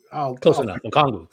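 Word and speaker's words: Okongwu: I'm trying to think, you know, Okwandu. I butcher his name all the time. Okwandu, I think. – Okongu. – Okwandu. Thank you.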